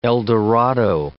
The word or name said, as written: El Dorado